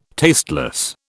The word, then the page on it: tasteless: British English